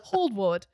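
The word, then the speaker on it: Hould-Ward: The costumes were by Anne Holdward, I think is how you say her name, Miss Goose. – Hogsport. – You are a Tony Award. – Hogsport. – Hold. H-O-U-L-D. Not Hogsward. Holdward.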